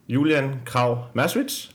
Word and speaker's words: Julian Krav Maswitz: Ja, Vores gæster i dag er jo øh, Julian Krav Maswitz, var det rigtigt udtalt? Ja.